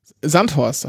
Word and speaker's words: Sandhorst: Sandhorst, sagt man. Sandhorst. Betonung ja. auf der ersten Silbe.